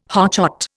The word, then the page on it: hotshot: Indian